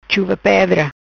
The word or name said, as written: Chuva Pedra